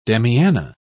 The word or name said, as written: damiana